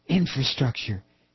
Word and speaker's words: infrastructure: Keep saying infrastructure. Accent on the first syllable, George.